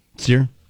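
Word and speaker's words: Cyr: It's C-Y-R. Sear? – Sear.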